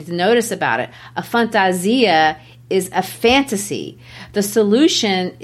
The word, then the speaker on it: fantasia: If the inevitable cannot be avoided forever, perhaps the solution is to wear, and the word is pronounced fantasia in Portuguese. That's their Portuguese word for costume,